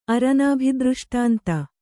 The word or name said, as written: ಅರನಾಭಿದೃಷ್ಟಾಂತ